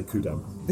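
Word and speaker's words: Ku'damm: We just say Kudamm. – The Kudamm.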